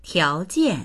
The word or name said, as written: tiao2jian4